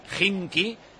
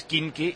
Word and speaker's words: Khimki: En caso de que se clasifique para la final de la Eurocup, será el Khimki. Porque he dicho antes Khimki y me ha dicho Pedrito Barín que se pronuncia Khimki.